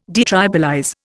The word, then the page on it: detribalize: Indian